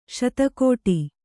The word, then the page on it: ಶತಕೋಟಿ: kannada